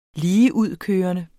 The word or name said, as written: ligeudkørende